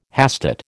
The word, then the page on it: hastate: American English